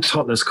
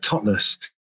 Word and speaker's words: Totnes: So people who are kind of from Totnes call it Totnes, people who aren't, they call it Totnes, like Loch Ness. If you pronounce it Totnes, it gets frowned upon by some people.